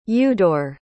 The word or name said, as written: eudor